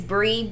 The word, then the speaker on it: Bri: And Reese is spelled R-E-E-S-E. Yeah. – Okay. follow me at it's Brie, B-R-I dot bih, like bitch, but bih, B-I-H.